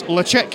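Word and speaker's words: Ledaig: Deanston, but they're also representing Ladaig, which apparently, in Gaelic, you pronounce Lechick.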